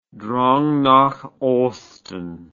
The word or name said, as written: Drang nach Osten